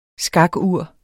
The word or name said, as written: skakur